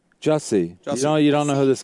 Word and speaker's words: Jussie: I thought it was Jussie, but then I was hearing Juicy, but. – Oh. – I'm thinking Jussie. – Jussie Smollett.